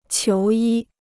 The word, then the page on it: 求医: qiú yī